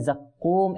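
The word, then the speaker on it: জাক্কুম (زقوم): দেখুন এখানে জাক্কুম, এটা হচ্ছে কফ, এটা কাফ নয়, ঠিক আছে? তো এটা আমরা কফ উচ্চারণ করব।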